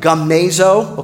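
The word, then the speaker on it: gumnazo (γυμνάζω): That word is spelled G-U-M-N-A-Z-O. That's, of course, in English. Those letters are not in the Greek language. It looks like gumnazo. Okay, it's really pronounced goom, gumnazo. Okay?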